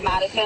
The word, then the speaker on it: Madison: My like legal name is Madison.